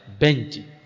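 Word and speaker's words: Bench (بنج): Bahasa Arabnya beng. Ba nun jim. Bacanya apa? Bench.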